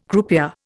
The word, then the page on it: croupier: Indian